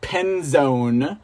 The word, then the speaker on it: Penzone: This jabroni is Sheriff Penzoni the Jabroni. You guys remember who he is, right? Okay, it's pronounced Penzone.